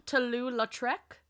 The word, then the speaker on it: Toulouse-Lautrec: Toulouse-Lautrec.